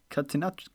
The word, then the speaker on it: Catenaccio: Wie spricht man das aus? – Jetzt. – Catenaccio. Catenaccio. Nicht Catanaccio.